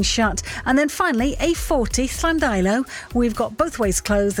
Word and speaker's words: Llandeilo: I was going to say excellent pronunciation of Llandeilo.